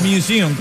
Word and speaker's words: Museum: O museum, ¿cómo se pronuncia? – Museum. – Museum, ah, eso.